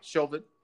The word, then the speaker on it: Chauvin: Well, we're Officer Chauvin, I'm not sure if I'm pronouncing Chauvin. That correctly. – Yeah, Chauvin.